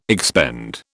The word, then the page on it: expend: British English